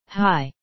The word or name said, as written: high